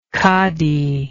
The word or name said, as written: cadi